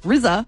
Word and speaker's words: RZA: Yeah, so she posted a video with the Mission Impossible theme song playing over it while her 2-year-old RZA spelt like scissor with an R.